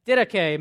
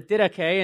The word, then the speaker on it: didache: There's also this thing called the didache. Maybe you don't know what the didache is. It's spelled like that. It's not didach, which is how I always pronounced it. It's called the didache.